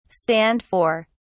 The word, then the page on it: stand for: British English